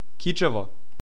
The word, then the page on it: Кичево: ˈkitʃɛvɔ